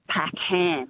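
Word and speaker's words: Packham: So my surname is Pac-Ham, H-A-M. And she called me Ann Pac-Man.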